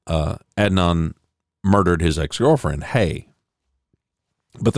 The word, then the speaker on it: Adnan: A classmate at Woodlawn High School says she knows where Adnan, what did I say? I think I mispronounced his name the first time, where Adnan was.